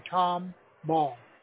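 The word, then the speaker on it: Tomball: K-L-E-I-N. – Okay. Klein. – Spring. – Okay. – Spring, Texas. – Okay. – Comalls, Texas. – How do you spell that? – T-O-M. The Tom Ball.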